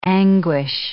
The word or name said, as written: anguish